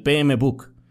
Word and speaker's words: PMBOK: Yo decía el PMBOK. Pero realmente, o sea, no es book, es buck.